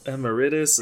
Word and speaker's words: Emeritus: So, while other members of the band would wear black hooded robes and be called nameless ghouls, Forge would go by, I'm going to butcher this because I don't say it out loud, Papa Emeritus? – Emeritus, emeritus. I've heard it a lot of different ways, just from people in different countries.